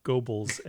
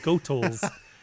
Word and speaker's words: Goethals: I pronounced his name wrong. It's not Goebbels, and that's why it's terrible. – Oh. – It's Goethals in German.